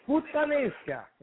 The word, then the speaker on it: puttanesca: Puttanesca.